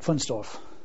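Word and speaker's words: Wunstorf: At Pfunstorf.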